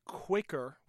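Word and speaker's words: Kveikur: It's called Quaker, which is not spelled like that, but it's called Quaker.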